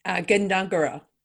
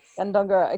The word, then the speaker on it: Gundungurra: And Elle is hailing us all the way from the traditional lands of the Darug and Gundurraga people. Have I pronounced that correctly? – Gundungurra. – Gundungurra, okay.